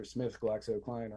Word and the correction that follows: GlaxoSmithKline: or GlaxoSmithKline or whatever.